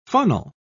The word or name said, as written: funnel